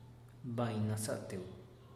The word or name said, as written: bain naza'ateu